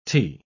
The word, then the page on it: -ty: British English